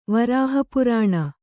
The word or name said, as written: ವರಾಹಪುರಾಣ